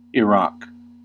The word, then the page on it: Iraq: /ɪˈɹɑːk/; /ɪˈɹak/; /ɪˈɹæk/; /aɪˈɹæk/